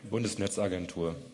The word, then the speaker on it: Bundesnetzagentur: Bundesnetzagentur. – Bundesnetzagentur? – Yes. – I said that correctly? – Okay.